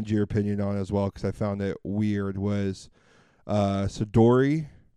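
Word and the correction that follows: Shedeur: I think it's pronounced Shador.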